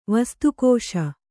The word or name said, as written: ವಸ್ತುಕೋಶ